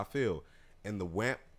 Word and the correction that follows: WAP: It's WAP.